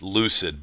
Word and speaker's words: Loosid: Well, I'll tell you, it's spelled L-O-O-S-I-D. It's Lucid, but it's spelled L-O-O-S-I-D.